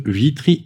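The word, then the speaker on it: Witry: Vitry Immo. Alors je dis, on dit Vitry. Oui, parce qu'il y a un W. Ça s'écrit avec un W.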